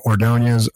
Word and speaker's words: Ordonez: My name is spelled Reggie, R-E-G-G-I-E, last name Ordonez, O-R-D-O-N-E-Z.